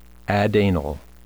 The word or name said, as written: adanal